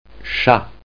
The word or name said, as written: chat